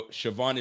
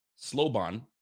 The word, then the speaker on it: Siobhan: Yo, Siobhan is how you say it. Oh, Siobhan. Hold on a second. I see an L in there. Slobon, but it's really Siobhan.